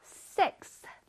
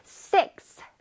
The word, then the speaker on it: sixth: X 다음에 TH 발음이 오기가 어렵거든. 우리 한번 해볼까요? Sixth. Sixth.